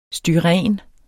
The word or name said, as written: styren